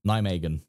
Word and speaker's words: Nijmegen: I think that's how you say it. – Nijmegen.